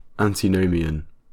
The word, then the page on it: antinomian: British English